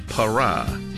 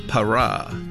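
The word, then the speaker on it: Parah: 바라는 영어로 이렇게 발음합니다. 파라, 파라, P-A-R-A-H예요. 파라. 한 번 더요. 파라. 바라를 파라. 이렇게 연습해 주시기 바랍니다.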